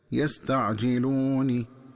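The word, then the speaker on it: يستعجلونِ: وكذلك عند وصل آخر هذه الآية بالآية التي تليها ينبغي التنبه للنطق الصحيح للفظ يستعجلون، فيقرأ بكسر النون هكذا يستعجلوني.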